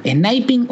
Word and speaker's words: sniping: ¿Sniping o snipping? ¿Cómo se dice? Sniping. Sniping, sniping.